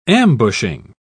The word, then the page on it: ambushing: British English